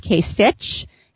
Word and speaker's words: Kasich: Interesting thing to note, you will sound really in the know if you pronounce his last name correctly. It looks like it's spelled Kasich, and it's actually pronounced Kasich, like a K at the end.